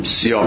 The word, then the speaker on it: CIA: CIA که چنان اختصاری میشه که دیگه به همین شکل مقطع تلفظ میشه و آرام گاهی هم به شکل مقطع تلفظ نمیشه، سیا میگن، گاهی هم میگن CIA ناجا مثلا.